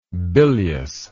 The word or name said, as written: biliousness